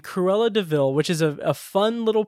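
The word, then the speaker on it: De Vil: And then I feel for Paul Walter Hauser because he has to say the line, like, it's spelled like devil, but it's DeVille.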